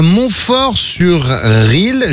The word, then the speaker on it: Montfort-sur-Risle: Tu es à Montfort-sur-Risle, je le prononce bien? – Oui, c'est tout à fait ça.